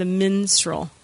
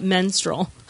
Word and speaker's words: minstrel: Yeah, let's pronounce it properly. The minstrel, not menstrual. The minstrel.